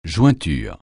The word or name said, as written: jointure